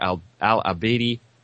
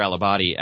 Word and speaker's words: Al-Abadi: The operation to take Tikrit began early Monday morning after being announced by Prime Minister Al-Abadi, wow, I can't believe I mispronounced his name, after being announced by Prime Minister Al-Abadi after the previous evening.